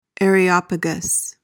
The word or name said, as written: areopagus